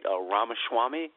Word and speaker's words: Ramaswamy: I don't believe this candidate, I don't know if I'm pronouncing his name that right, Ramaswamy.